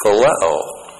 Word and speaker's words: phileo: But every single time that Peter answers, he answers with the verb phileo. P-H-I-L-E, long O.